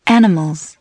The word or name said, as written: animals